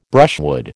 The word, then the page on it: brushwood: American English